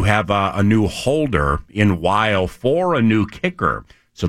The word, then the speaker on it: Wile: Damn it, I was hoping it was Wiley. W-I-L-E looks a little bit like Wiley, but it's pronounced Matt Weil.